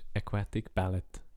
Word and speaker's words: Aquatic Ballet: Ähm, und ich sage den Titel, weil der Matthias Ballet nicht kann aussprechen Das ist Aquatic Ballad.